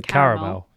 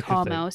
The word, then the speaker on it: Carmel: And I thought, oh, is that just the Americans butchering the caramel? – No, it That's is Carmel, C-A-R-M-E-L, after a mountain in Israel, mentioned in Joshua 19:26 in the Bible's Old Testament.